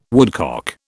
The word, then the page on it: woodcock: British English